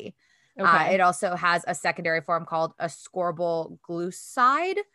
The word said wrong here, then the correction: glucoside: I think it's how you say it. Glucoside.